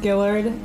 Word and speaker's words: Gillard: Mine is Annie underscore Gillard.